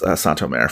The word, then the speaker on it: Saint-Omer: I'm now taking my cue for how to pronounce Saint-Omer from Taylor Page, because that's how I'm deciding to go for it. – You have a French inflection with it.